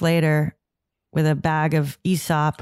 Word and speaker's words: Aesop: and that's how it's pronounced, Aesop.